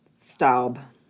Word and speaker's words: Staub: Stubb? How do you say her name? – Staub.